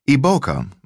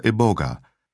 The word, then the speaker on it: iboga: Iboka, also known as iboga, the pronunciation that I will use from now on, is classified scientifically as Tabernantha iboga and is a member of the Apocynaceae dogbane family.